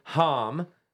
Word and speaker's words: Hamme: By the way, so we've got DG and Dave Hom. Apologies earlier, I butchered his last name. – You're not the first. It is not Ham. It is Hom.